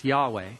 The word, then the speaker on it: Yahweh: The English letters are YHWH, sometimes pronounced Yahweh, and the meaning is I am.